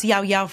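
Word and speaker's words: Llao Llao: It's Spanish, so it's Yao Yao frozen yogurt. Two L's being the Y.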